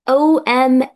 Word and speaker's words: awesome: awesome يعني حاجة جميلة أوي فوق الوصف، يعني بتتكتب AWESOME، awesome awesome، ركزوا في النطق awesome، مش awesome بقى والحاجات دي، awesome awesome. boring boring، this place is boring، معناها مكان ممل، ممل قوي، ممل يعني خفوق انا مش يعني ممل من الاخر.